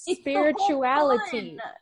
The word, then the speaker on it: SpiritualiTEA: Firstly, thank you for saying spirituality, because so many people call me spiritual tea, and it's like they're forgetting the second I at the it's spirituality. – It's the point. – That's why I put it in my TikTok bio pronounced like spirituality, because so many people were calling me spiritual tea.